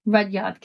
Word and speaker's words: Rudyard: I did get told off for pronouncing in episode one when we're talking about our old books and I said it wrong, and it's Rudyard Kipling, which I still sound so weird to say it properly. – What'd you say in the I think I said Rudyard. – And what is it? – Rudyard. Rudyard Kipling instead of Rudyard.